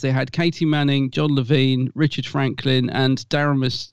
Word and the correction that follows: Damaris: oh, I can never say her name right. Let me get it right. I was doing that all day as well. Damaris, isn't it?